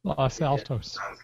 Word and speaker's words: Los Altos: Los Altos.